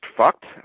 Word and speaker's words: FUCT: So similarly here, the case was brought or involves an attempt to register the F-U-C-T pronounced fucked, just like, you know, you might have just thought that I was pronouncing a swear word.